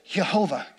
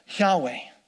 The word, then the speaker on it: Yahweh: For years, because of the influence of Latin, people thought it was pronounced Jehovah, Jehovah. But now we believe it's pronounced Yahweh. That would be more of the Hebrew way.